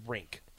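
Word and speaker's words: Rienk: We have gotten confirmation from Mr. Mark Onweiler that it is pronounced Rink.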